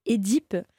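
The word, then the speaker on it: Œdipe: tout comme « édipe », qui, voilà, les profs de lettres qu'on a à la fac, ils disent « c'est bien édipe » parce que ça vient du grec, etc.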